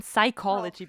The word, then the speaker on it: psychology: And psychology, oh, because where does the P go?